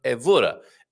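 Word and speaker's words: Évora: And yes, Carl, second to last syllable stressed, unless an accent mark. So, Evora, Evora. Actually, I'm sure on Wikipedia, I had a, you know, you can press the pronunciation little icon there. I'm sure the guy said Evora, but Evora, Evora, Evora.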